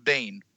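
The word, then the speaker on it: Biehn: His, his name is Michael um, Bean. Michael Bain. – Michael Bean. – Michael Bain. Yeah, I think it's pronounced Bane.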